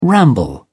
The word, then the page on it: ramble: British English